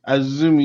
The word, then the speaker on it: Azumi: And first, the women, the 3-way match for the AWGP Women's Championship, Mercedes Moné retaining her championship against, You told me you pronounced Azumi. – Azumi, even though there's no U. Okay, got you. – But that's how the letters are pronounced in Japanese. They don't say the letter is Z, they say it is Zu.